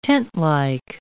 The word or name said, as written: tentlike